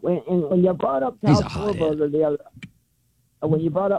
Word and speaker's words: Schwarber: Get his name right. – Schwarber. – Yes.